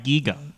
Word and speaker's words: Giger: It's Giger. I thought there was an E in his name. – See, I said it right, Giga.